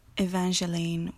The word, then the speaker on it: Evangeline: Emil, Endymion, Eulalie, Evangeline with a soft G.